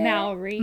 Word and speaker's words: Maori: So he was raised by a Maori. Is that how you say it? – Maori mother.